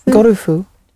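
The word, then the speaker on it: Gorufu (ゴルフ): So with golf, you're breaking it down into a consonant vowel sound, gorufu. And each of those sounds, apart from the go, the other two are followed by an u sound, gorufu, gorufu.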